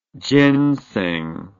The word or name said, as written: genseng